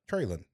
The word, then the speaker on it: Treylon: Treylon. That's apparently that's how he pronounces it. Ignore that O.